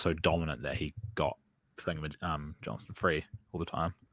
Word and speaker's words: Johnston: Alex Johnson. Why does it sound weird? Am I pronouncing his last name wrong? – Alex Johnston.